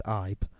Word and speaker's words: Ibe: It's either Ibe or Ibe. I don't know how to say it. – Over here, we correctly say it as Ibe.